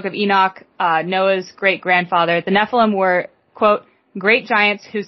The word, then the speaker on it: Nephilim: According to the Encyclopedia Britannica, the term for giant is known as Nephilim, pronounced Nephilim. Nephilim.